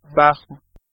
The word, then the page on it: bajo: /ˈba.xo/